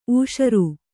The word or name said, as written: ಊಷರು